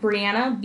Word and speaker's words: Breanna: I'm at Breanna,